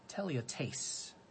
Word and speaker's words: teleiotes: The word for perfecter is teleotes. That's spelled T-E-L-E-I-O-T-E-S, teleotes.